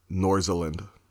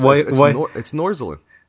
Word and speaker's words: Nordjylland: You're going to laugh at me for knowing this. Nordjylland. – Really? – Yep. – That's disappointing. – It doesn't look anything like it, does it? – No. Why? – It's Nordjylland. – Why have a J and a weird and A and an E? if it's going to be pronounced that way. – And completely bypass the D.